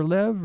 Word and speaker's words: Lev: And it's pronounced Leave or Lev or? – It's pronounced Lev.